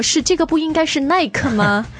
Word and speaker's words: Nike: 现在我觉得越来越多的人会说 Nike, 但是耐克刚进中国的时候我们按照标准英文发音的规则都会读耐克。应该是 Nike。